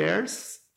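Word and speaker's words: Ayres: E nós temos a integração sensorial de ERS, é, se escreve A-Y-R-E-S, é, que é implementada por terapeutas ocupacionais com essa formação e que é indicado para é, é desregulações sensoriais, né, desordens sensoriais.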